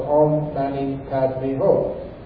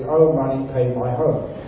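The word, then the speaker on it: Om Mani Padme Hum: Can you kindly share about the meaning of Om Mani Padme Ho? That is very famous and presented by Singapore. That's a rock pronunciation. It is Om Mani Pay My Ho.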